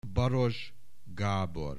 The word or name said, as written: Baross Gabor